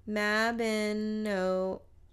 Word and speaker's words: Mabinogion: It is spelled Mabinogion. Yeah, Mabinogion.